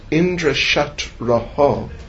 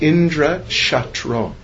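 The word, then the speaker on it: Indraśatru: He mispronounced the last word. He pronounced it not Indra Shatraho, but Indra Shatro. It's got a different meaning.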